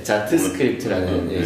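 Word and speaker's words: Jot Script: 몇 가지 제품들이 좀 더 추가됐고 그 다음에 이제 제일 관심이 있었던 거는 이제 자스크립트라고 해서 이 발음 조심해. – 네. 네. 자트스크립트라는 네.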